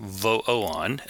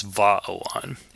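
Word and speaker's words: Vaoan: One, the demonic version, Vo'oan, and then he, corrects himself and says, no, actually the way to pronounce it with good angels is Va'oan.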